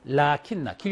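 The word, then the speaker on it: 라킨나 (لٰكِنَّ): 라킨나 같이 쓸 때, 이게 라가 길게 장모음이 있어요. 대거 알리프가 있습니다. 라킨나. 길게 발음하세요. 라킨나 하지 말고, 라킨나. 네, 라킨나 발음하세요.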